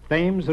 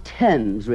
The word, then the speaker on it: Thames: I should probably end it all by leaping into the Thames River. – Oh, Mr. Marx, it's pronounced Thames River.